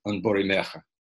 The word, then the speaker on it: Un Bore Mercher: I'm not a Welsh speaker myself, but I've been with the project long enough to know it's pronounced something like Un Bore Meche.